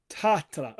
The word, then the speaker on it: Tähtälä: So how would you say your name properly? – Tuomas Tähtälä. – Tähtälä. Okay, so the H does actually mean something.